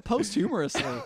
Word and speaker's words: posthumously: This is the first time, and I was like, why would they spell it posthumously? – Posthumously. – Oh, there's no R there.